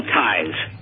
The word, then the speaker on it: Ties: I was talking about this Global Size Foundation, which is huge, and this ambassador... – How do you say Size Foundation? – This is Global Ties. – Ties, T-I-E, Ties Foundation. Okay.